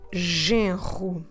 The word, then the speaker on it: genro: The word for son-in-law is quite difficult to pronounce because of a r sound followed or preceded by a nasal. So I'm talking about the word genro.